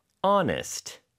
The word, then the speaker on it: honest: There's an H hiding at the beginning. H-O-N-E-S-T. – 이거를 honest라고 하는 사람이 있나요? – 없습니다. – 없어요. H는 이렇게 묵음인 경우가 가끔 있어요. 물론 집은 무조건 house, H 발음을 내야 되겠죠. house라고 하는 사람은 없으니까요. 근데 네. honest는 H 발음을 하는 경우가 없습니다.